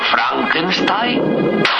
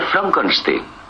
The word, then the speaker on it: Frankenstein: ¿Doctor Frankenstein? Frankenstein. ¿Me toma el pelo? No, se pronuncia Frankenstein.